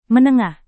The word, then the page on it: menengah: Indonesian